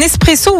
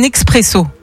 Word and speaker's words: espresso: Alors tiens, est-ce que tu dis un espresso ou un expresso? – Pour le café. – Oui. Ouais. – Espresso. – Espresso, ouais. Étymologiquement, on ne prononce pas le X.